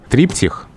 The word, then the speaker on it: триптих: А я вот э, не могу произнести слово «триптих» с ударением на первом «и», потому что помню Высоцкого.